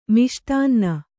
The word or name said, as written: ಮಿಷ್ಟಾನ್ನ